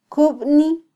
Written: kubni

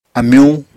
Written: amyuñ

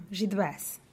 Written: givas

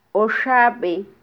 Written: oxabe

